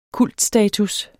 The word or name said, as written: kultstatus